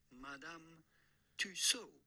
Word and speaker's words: Tussauds: How do you go about pronouncing it? Madame Tussauds.